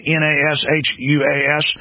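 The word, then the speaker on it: Nashua: WSMN, 1590 AM, New Hampshire, Nassau's News and Talk. Am I pronouncing that right? Nashuas.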